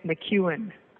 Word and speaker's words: McKeown: Essentialism by Greg McEwen. – Yeah, that's spelled McEwen. – Right. – Not your intuitive spelling.